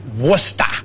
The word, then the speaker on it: Worcester: It's pronounced Worcester.